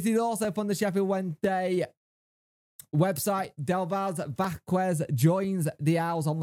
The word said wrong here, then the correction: Davis: You got his first name wrong. It's Davis.